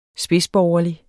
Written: spidsborgerlig

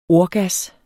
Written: ordgas

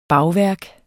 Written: bagværk

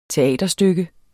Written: teaterstykke

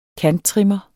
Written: kanttrimmer

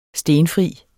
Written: STENFRI

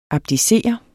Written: ABDICER

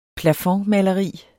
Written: plafondmaleri

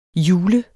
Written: hjule

